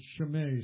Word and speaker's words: Shimei: There's an interesting name there, Shimei. Some people will call it Shimei. It doesn't matter to me how you pronounce it. It's got an E-I at the end, so I that's an A to me.